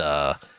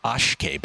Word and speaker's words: Akshay: For those wondering, I pronounced Akshay Bhatia, Ashkay Bhatia. So, my bad.